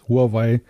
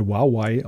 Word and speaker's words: Huawei: Darf ich noch ergänzen, dass das Huawei, wie es ja im Deutschen oft genannt wird, in Wirklichkeit Huawei ausgesprochen wird? – Ja, ich mir mal das sagen weiß lassen.